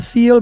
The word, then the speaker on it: Seal: O sea, se pronuncia SEAL, pero es SEAL, que es foca, sería.